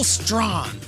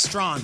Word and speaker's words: Strachan: It is Colts wide receiver Michael Strawn. Uh-huh. Now, his name is spelt Strachan, like the Hall of Famer Strahan with a C, but it's pronounced Strawn. His story is incredible.